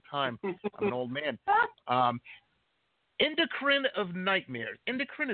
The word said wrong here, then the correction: Enchiridion: is that the proper pronunciation? It means like a manual, right? – Hang on, my buddy boy. Enchiridion.